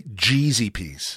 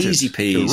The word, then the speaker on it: Jeezy Peas: Geezy Peas. – Hang on. It's apparently Jeezy Peas. – Oh, of course. Yes, it was pointed out. It's corrected. Jeezy Peas. – It rocked my world. – To rhyme with cheesy peas.